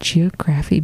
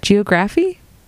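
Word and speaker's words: Geographe: Geograph Bay. Because it's spelled with an E at the end, not in a Y. So I didn't know how to say it.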